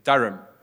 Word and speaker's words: Durham: This was a professor at the University of Durham.